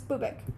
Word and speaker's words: Bubak: It's spelled B-U-B-A-K, but I looked it up how to say it, and it's Bubak.